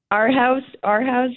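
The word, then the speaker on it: Our Hauz: Our House, sure. Either way. – Okay, it's spelled H-A-U-Z, so I just wanted to be clear on that.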